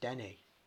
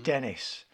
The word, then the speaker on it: Denis: It's pronounced Denny. – Denny. Well, it depends. Obviously, that's how he pronounces it because he's French-Canadian. But we pronounce it because we're English. As mm-hmm. Dennis.